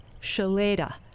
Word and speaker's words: Shloeta: Spelled S-H-L-O-E-T-A and pronounced Shalada.